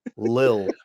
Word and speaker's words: Lil: a little disappointed. – My guy. – Oh, and now here's Little Wayne. I'm sorry, Lil. I, I believe I mispronounced Lil Wayne. I said Little.